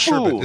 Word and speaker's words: sherbet: And it's pronounced sherbet, isn't it? You don't pronounce that. So even though that second R is in there, you don't pronounce it.